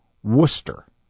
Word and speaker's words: Worcester: Because the way it's spelled is Worcester. But I say Worcester, which is cool.